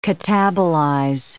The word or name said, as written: catabolize